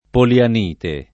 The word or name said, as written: polianite